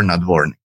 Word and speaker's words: Nadvornyy: It's Yegor, with the emphasis on the O, and Yegor Nadvornyy.